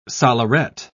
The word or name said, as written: solleret